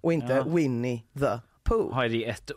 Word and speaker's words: Winnie the Pooh: Närmare bestämt filmen Winnie the Pooh, eller för, förlåt, så här uttalas den, Winnie the Pooh, Blood and Honey.